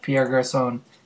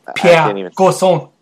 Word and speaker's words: Garçon: I don't see how you cannot have Pierre Garçon in your lineup. – All right, so Pierre, I think you're saying it wrong. I think it's Pierre Garçon.